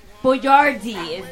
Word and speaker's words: Boyardee: I dub you Chef Boyardee. – No, wait, it's Chef Boyardee. Boyardee. – They spelled it phonetically.